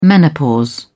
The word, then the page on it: menopause: British English